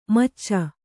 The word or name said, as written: ಮೋಚ